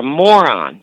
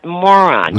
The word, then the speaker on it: Moran: A guy asked me to spell it, and I said, M-O-R-A-N. He said, you pronounce that moron.